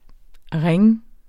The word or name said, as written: ringe